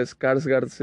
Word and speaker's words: Skarsgård: Em, esta película en donde sale Bill scars ah, Bill Scars. Dilo tú, dilo tú. Eh, Bill Almendra. Bill Almendra, eh, dice mi hermano. Eh, no sabía que Skarsgård significa.